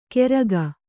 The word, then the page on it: ಕೆರಗ: Kannada